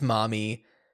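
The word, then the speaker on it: Marmee: It's Marmy. – I read probably on Wikipedia or something that it was likely intended to be pronounced Mommy, but because of the dialect.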